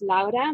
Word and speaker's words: Laura: But I always introduce myself as Laura because that's my name. Uh-huh. That's how we pronounce it in Dutch as well.